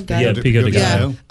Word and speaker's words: pico de gallo: Some pico, how do you say it? Pico de gallo? – Pico de gallo.